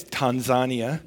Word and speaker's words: Tanzania: And yes, it's Uganda, just like it's Tanzania. So our Canadian pronunciations of most African countries is not exactly the way that they would pronounce it.